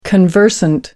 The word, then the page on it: conversant: American English